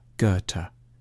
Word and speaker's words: Goethe: A safe bet is to start with a hard G on Ge and end with a T, Goethe.